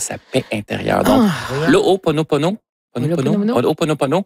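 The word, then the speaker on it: Ho'oponopono (Hoʻoponopono): Non, c'est une philosophie hawaïenne de, je vais le prononcer comme il faut, de Ho'oponopono. Le Ho'oponopono.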